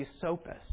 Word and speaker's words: Esopus: One was called the Lenapes, and the other was called the Esopus, pronounced exactly the way she said it. You started and then you corrected it. And what you said the second time is the exact way it's pronounced.